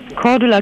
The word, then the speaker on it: Cordula: Cordula Kine.